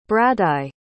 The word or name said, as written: bradaigh